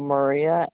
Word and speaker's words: Maria: Maria Castro. – Spell that. You said Maria? – Lori? – No, Maria, M-A-R-I-A. – Okay. – Castro, C-A-S-T-R-O. – You said C-R. – No. C-A-S-T-R-O.